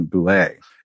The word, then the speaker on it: Boulay: We've got some amazing stories, and we have one of those today with Mr. Jason Boulay. Did, am I, I, I'm guessing that's the right pronunciation. Am I getting it right? You got it.